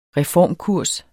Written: reformkurs

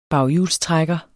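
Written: BAGHJULSTRÆKKER

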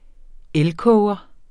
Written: elkoger

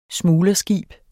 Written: smuglerskib

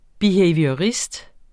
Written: behaviorist

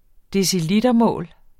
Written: decilitermål

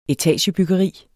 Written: etagebyggeri

